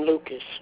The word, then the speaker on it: Lucas: My name is William Lucas. – Can you spell that? – William, L-U-C-A-S.